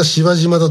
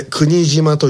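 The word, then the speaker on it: 柴島: みんな芝島だと呼んでしまいます。阪急千里線の駅であります。これ国島と読みます。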